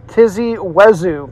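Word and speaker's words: Tizi Ouzou: Uh, Tizzy or Thizzy Wezu. T I Z I. New word O U Z O U. Tizzy Wezu.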